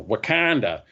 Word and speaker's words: Wakanda: So they're different locations in the Marvel universe, like, you know, Wakanda.